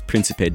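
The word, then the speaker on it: Príncipe: I keep pronouncing the label as Principe Discos. It's Principe Discos, the Portuguese label that specializes in Kuduro Batida, Taraxinha, and all kinds of other Portuguese dance music subgenres.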